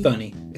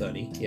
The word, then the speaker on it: Thunney: Thunney. It's pronounced Thunney.